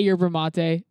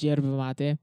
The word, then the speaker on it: yerba mate: You could say yerba mate, but it's pronounced yerba mate. It's a... – So the Y's... So where is this from? – It's from Uruguay. – Okay. So the Y's are pronounced like J's? – The Y's are pronounced like J's.